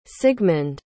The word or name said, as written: sigmund